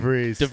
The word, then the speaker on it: de Vries: I don't know how to pronounce DeVries. – His last name. – DeVries.